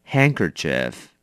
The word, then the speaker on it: handkerchief: 前面最早有 hanker 后面是 chief 那么小心它后面是念成短的所以要念成 handkerchief